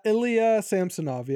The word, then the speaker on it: Ilya Samsonov: Is it Ila Samsonov? Is that how you pronounce that? – Ilya Samsonov, yeah.